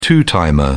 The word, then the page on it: two-timer: British English